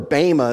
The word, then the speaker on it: Bema: Remember, this judgment this Bema or Bema is really how it's pronounced, but I'll go back and forth.